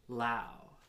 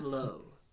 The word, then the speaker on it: Lowe: First, second baseman, leading off, Brandon Lau. Not Lowe. It is spelled like Lowe's, like that, the hardware store, but it's pronounced Lau.